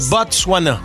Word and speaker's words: Botswana: Je suis pas sûr qu'il se prononce, le thé. – Oui, il se prononce. – Le Botswana.